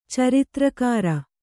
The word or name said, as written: ಚರಿತ್ರಕಾರ